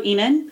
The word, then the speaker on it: Ihnen: I'm at Mezzo Enen. Mm-hmm. And my last name is spelled I-H-N-E-N.